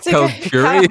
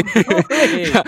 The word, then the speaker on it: Kalgoorlie: It's Kalgoorlie. Kalgoorlie. – I'm foreign. Kalgoorlie. Okay. Kalgoorlie. Okay.